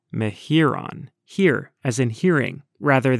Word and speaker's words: Mehuron: And it's pretty simple, Mehuron. – So that's the, say it one more time. – Mehuron.